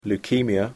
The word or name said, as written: leukemia